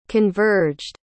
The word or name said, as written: converged